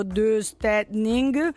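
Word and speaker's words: döstädning: E il nome di questo, di questa arte in svedese, adesso io non la so pronunciare, ma si scrive Dostadning, detto Dostadning. – Così, uguale, sì.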